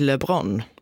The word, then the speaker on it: Lebrun: Och redan i första tävlingen så blev han utslagen efter att förlorat 4 raka set mot fransmannen Alexis Lebrun. – Där satt den i alla fall, det är uttalet.